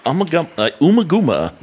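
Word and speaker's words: Ummagumma: And this week we will be discussing Amugama by Amugama? Is that how it's pronounced? – Amugama. – Amugama. Amugama by Pink Floyd. – Amugama. – Umaguma. – Umaguma.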